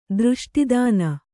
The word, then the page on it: ದೃಷ್ಟಿದಾನ: Kannada